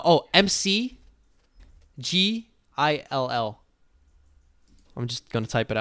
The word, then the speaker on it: McGill: Mac, McGill. – like McDonald's. – McDonald's, yes, my bro.